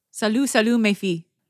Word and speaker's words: Salut, salut, mes filles: How do you say that, French ladies? – Salut, salut, mes filles.